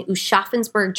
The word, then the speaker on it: Aschaffenburg: Hans Schmidt was born January 3rd, 1881 in Aschaffenburg, Germany, which is like a small town about 30 miles outside of Frankfurt.